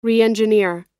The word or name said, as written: reengineer